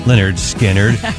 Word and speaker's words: Lynyrd Skynyrd: It was 53 years ago today, Lynyrd Skynyrd released their debut album, pronounced Lynyrd Skynyrd, with the classics Tuesday's Gone.